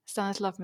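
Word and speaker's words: Stanisław: Und es ist, spricht man das aus, Stanislaw Lem. – Stanislav Lem, ja.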